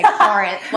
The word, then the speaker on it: Corinth: It's spelled C-O-R, but people from Corinth say Corinth, like Corinth. C-A-R.